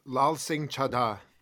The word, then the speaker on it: Laal Singh Chaddah: Is that how you pronounce it? – Laal Singh Chaddah. Laal they said Singh it a couple Chaddah. Times in the movie. That's how it's pronounced.